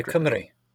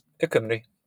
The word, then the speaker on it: Cymru: And how do you pronounce the chapter? – Ikumri. – Yeah, all right. – Yep. Just Ikumri.